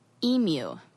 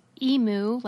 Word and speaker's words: emu: and apparently NPR decided to allow their journalists to pronounce it in the American way, which is how I say it, which is emu, like a cow, emu.